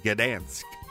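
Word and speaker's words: Gdańsk: Royster, how do you pronounce that town, that city in Poland? It's spelled G-D-A-N-S-K.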